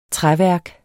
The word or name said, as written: træværk